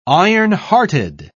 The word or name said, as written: ironhearted